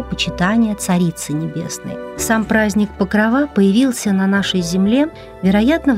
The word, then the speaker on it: Покрова: Обратим внимание на то, что в сочетании праздник Покрова мы ставим ударение на окончание Покрова.